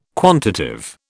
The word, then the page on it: quantitive: British English